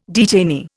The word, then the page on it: detainee: Indian